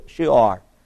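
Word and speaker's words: Shur: Now, Shoar is it looks like shore, but it's pronounced S-H-O-O, long O, and then slash O-R, Shoar.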